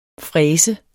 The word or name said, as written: fræser